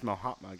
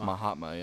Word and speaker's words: Mahatma: We don't even saying his name wrong for our whole life. – It's Mahatma. – Mahatma. – It's Mahatma, yeah.